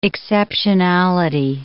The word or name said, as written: exceptionality